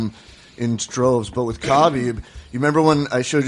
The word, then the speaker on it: Khabib: It's Khabib.